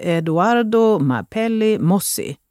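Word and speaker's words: Eduardo Mapelli Mozzi: I januari fick hon sitt andra barn tillsammans med sin make Eduardo Mapelli Mossi.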